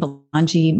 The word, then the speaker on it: Belonga: I also, on my Facebook, I have, it's Kathleen Belonga, Mindful Living and Yoga is the name of my business.